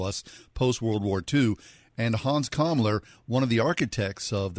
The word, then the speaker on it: Hans Kammler: But as you write in The Hidden Nazi, we have never heard the story of Hans Kammler. I hope I'm pronouncing his last name right. Is that right? – Yes, Hans Kammler is the guy. Exactly right.